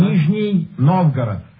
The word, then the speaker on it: Nizhny Novgorod: Nizhny Novgorod.